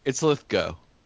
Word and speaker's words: Lithgow: It's Lithgow.